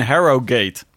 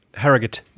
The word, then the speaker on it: Harrogate: En het WK is dus in Harrogate. Niet in Harrogate. Harrogate. Nee, Harrogate. – Harrogate. – Ja, heel goed.